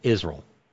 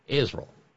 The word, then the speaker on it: Israel: Israel. That's how Mika says it. No, not you said three syllables. Mika says it's a two-syllable word. Israel. I-Z-R-U-L-L.